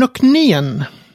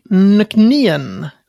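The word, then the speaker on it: Nc'nean: Eh, det, är, det är så här, nöknien. – Eller ja. – nöknien.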